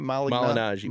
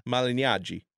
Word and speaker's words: Malignaggi: Malignaggi. If you really want to hear it the Italian way? – Yeah. – Malignaggi.